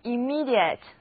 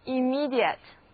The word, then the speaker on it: immediate: 注意单词重音在第二个音节，而字母组合 ia 在这里读作 ear。虽然表示直接的意思，但是这个单词读起来却是曲折得很，一定要多读啊 immediate immediate